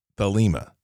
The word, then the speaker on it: Thelema: The word Thelema is an English translation from the Koine Greek noun, which is essentially pronounced Thelema, or will, from the and it means to will, wish, want, or purpose.